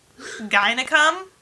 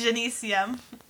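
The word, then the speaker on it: gynaeceum: And it's not pronounced gynecum, thank goodness. It's Genesium.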